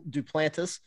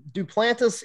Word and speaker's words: Duplantis: And I'm from South Louisiana, and Duplantis is one of those Cajun names that I tell people it's not as complicated as it looks. There's no silent letters. It's not like Boudreaux or Thibodeau where there's a bunch of silent E's, A's, U's, X's, whatever you want to call them.